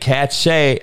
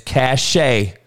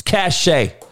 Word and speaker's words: cachet: It ain't cachet. It's cachet. How many guys saying that? That's cachet. Use it like cash and just add an E. It's cachet. You don't pronounce the fucking T on the shit.